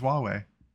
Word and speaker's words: Huawei: No, that's literally how it's pronounced. It's Huawei. Huawei.